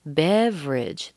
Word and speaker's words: beverage: Beverage. Ah, we're missing another syllable. Beverage. That second E. Beverage. So bev. Bev, nice and long and strong. Ridge. That's an R and a schwa and a J. Ridge.